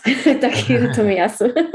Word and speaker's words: Takehiro Tomiyasu: The Takehiro Tomiyasu? – Yes, Takehiro Tomiyasu.